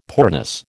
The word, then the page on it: poorness: American English